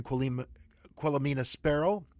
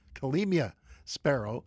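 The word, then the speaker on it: Quelemia Sparrow: It was written by Sebastian Archibald, Kevin Loring, and Quilomena Sparrow. I said that wrong again. Quilomena Sparrow.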